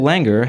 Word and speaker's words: Langer: Rachel Langer has heard of L. Ron Hubbard and plays violin. Her name rhymes with anger.